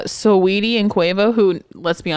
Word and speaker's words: Saweetie: Okay, I'm gonna say Saweetie. – Okay, it is Saweetie, but okay.